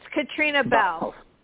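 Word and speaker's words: Caitríona Balfe: So anyway, it appears that Ariana DeBose, Kirsten Dunst, I would say Catriona Balfe, I can't pronounce it right, the woman from... – Belf. It's Katrina Belf.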